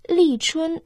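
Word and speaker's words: Ли Чун (立春): В китайском языке «Ли Чун» состоит из двух иероглифов. Первый иероглиф «Ли» произносится в четвертом тоне. В переводе это «начало», «старт». Второй иероглиф «Чун» произносится в первом тоне.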